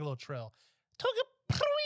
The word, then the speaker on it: Togepi: Wait, pronounce it again. – Tegepi. – Togepi. Like the Togepi. Pokemon.